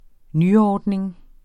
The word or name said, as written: nyordning